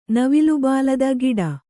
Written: ಗಿಡ

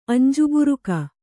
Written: ಅಂಜುಬುರುಕಿ

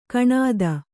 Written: ಗುಣ್ಣಿತ್ತು

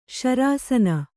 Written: ಶರಸೋನೆ

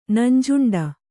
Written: ನಂಜುಂಡ

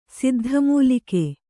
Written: ಸಿದ್ಧಮೂಲಿಕೆ

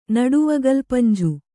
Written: ನಡುವಗಲ್ಪಂಜು

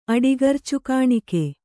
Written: ಅಡಿಗರ್ಚುಕಾಣಿಕೆ

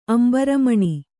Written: ಅಂಬರಮಣಿ

